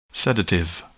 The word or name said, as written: sedative